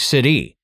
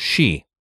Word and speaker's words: sidhe: Well, just the old Celtic for she, the type of fairy, which I'm going to mention in a minute, is S-I-D-H-E. So you would think city, but it's everything you look up, it's pronounced she.